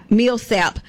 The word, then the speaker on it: Milsap: Now, the location is Lawson State Bessemer Campus in the MISLAP, am I saying that right? MILSAP Industrial Training Center.